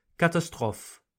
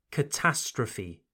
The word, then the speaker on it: catastrophe: As with recipe, we pronounce the final E sound. Catastrophe. Okay? So it's not quite the same as the French catastrophe. No, it's catastrophe.